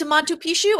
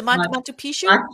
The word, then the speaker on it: Machu Picchu: You're going to Machu Picchu. I'm not saying it right. Am I saying it correctly? Is it Machu Picchu? – Machu Picchu.